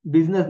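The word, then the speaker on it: busyness: बिजीनेस इन योर माइंड बिजनेस नहीं बिजीनेस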